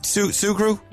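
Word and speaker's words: Sugrue: Am I pronouncing that right, Kira? Sugru? – You sure are.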